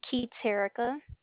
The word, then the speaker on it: Keterica: Keith Erica. – Spell it, please. – K-E-T-E-R-I-C-A.